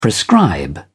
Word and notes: prescribe: British English

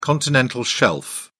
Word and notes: continental shelf: British English

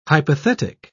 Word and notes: hypothetic: British English

